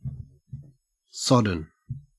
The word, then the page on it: sodden: Australian English